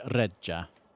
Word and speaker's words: reggia: I'm really impressed with your pronunciation of the word reggia. You rolled the R, you doubled the G.